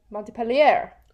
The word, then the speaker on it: Montpellier: Montpellier!